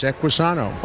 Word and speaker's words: Equisano: Well, the product that that you are here to talk about today, Equisano, am I saying it correctly? – You're right. Sano means health in Spanish. Equi for horse, Equisano.